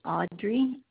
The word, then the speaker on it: Audrey: My name is Audrey, A-U-D-R-E-Y, last name L-E-H-M-A-N.